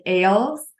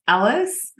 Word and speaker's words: Ahles: So it looks more like Ahles, but she just let me know that it's Ahles, pronounced like Alice in Wonderland.